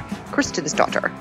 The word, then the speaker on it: Kristinsdottir: And of course, thank you to our amazing production team over at Stitcher, Nora Ritchie and Andy Christensdotter. – And note, Andy is not my daughter. Her last name is spelled K-R-I-S-T-I-N-S-D-O-T-T-I-R, Christensdotter.